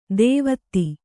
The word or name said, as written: ಧಾವತಿ